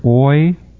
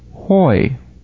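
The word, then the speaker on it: hoi (οἱ): The rough breather is pronounced as though you put in letter H at the beginning of the word. So instead of saying oi, it would be pronounced correctly hoi, the h sound, the H sound.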